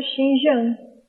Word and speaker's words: Xinjiang: Como é que pronuncia esse que eu leio Xinjiang? – Se tiver como o pinyin que a gente costuma levar no chinês, daí seria Xinjiang.